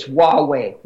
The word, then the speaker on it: Huawei: How do you pronounce that Chinese company name? – Huawei or whatever. – I think it's Huawei. Huawei.